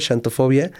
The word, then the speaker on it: xantofobia: Bueno, se escribe con X, santo, xantofobia.